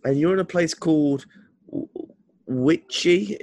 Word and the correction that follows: Wichita: if I said that correct? – Wichita. – Wichita, sorry. I apologize for my poor pronunciation.